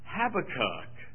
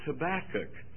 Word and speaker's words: Habakkuk: And he said, little brother, that's not how you say it. It's not Habakkuk. It's Habakkuk. Well, he was right.